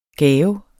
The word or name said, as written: gave